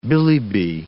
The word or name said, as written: billy-bi